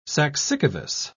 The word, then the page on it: saxicavous: British English